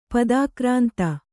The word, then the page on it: ಪದಾಕ್ರಾಂತ: padākrānta; Kannada